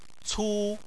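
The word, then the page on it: cu: tone 1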